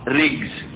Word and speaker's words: رجز: بل إن كلمة رجز كما تأتي الرجز، شايف تنطق ازاي؟ بكسر الراء، رجز، ري ري، سمعها؟ تأتي بكسر الراء.